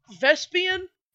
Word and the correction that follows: Vespasian: Vespasian. I feel like I am... – Vespasian? – That's how it's spelled. I keep calling him Vespian, though.